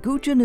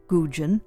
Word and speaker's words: Gougeon: And Gugin is spelled G-O-U-G-E-O-N. Dr. Len Gugin, G-O-U-G-E-O-N.